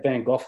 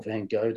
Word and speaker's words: Van Gogh: And if you have a look at his form, he had Van Gogh or Van Gogh, depending on your pronunciation, 3 lengths back when taking out the Juvenile Stakes last month.